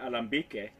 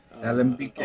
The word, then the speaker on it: Alambique: Yeah, the way we pronounce it, it looks French, and it is actually, but in Spanish they pronounce it Alambique. – Alambique.